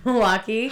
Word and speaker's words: Milwaukee: But it's really pronounced Milwaukee. – How do we know? – Well, yeah, we don't know, but Alice Cooper did say, actually, guys, it's pronounced Milwaukee.